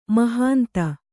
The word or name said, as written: ಮಹಂತೆ